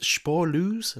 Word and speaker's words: Spoorloos: Is it Spoorloos? Is that how it's pronounced? The Vanishing. – I believe so.